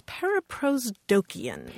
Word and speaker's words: paraprosdokian: It's a term of rhetoric, and it's paraprosdokian. – Paraprosdokian. – Yes, let me spell that. P-A-R-A-P-R-O-S-D-O-K-I-A-N.